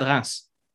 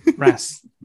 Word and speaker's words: Reims: Stade Reims. – Reims. Okay. – Reims.